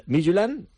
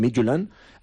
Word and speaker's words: Midtjylland: Y la Real Sociedad tiene que enfrentarse también al Midtjylland, que así es como dicen en Dinamarca, como pronuncian, Midtjylland,